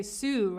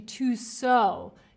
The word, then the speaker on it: sew: Oh, Swati, you pronounce that word to sew as if it had an O.